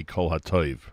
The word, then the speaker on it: Kol HaTov: You heard Yummy Lowy with Kol HaTov. Bo Near Code, that was Michal Przanski. Yeah, the proper pronunciation, or the actual pronunciation of the Yummy Lowy song, I guess, would be Kol HaToiv.